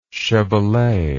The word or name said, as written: chevalet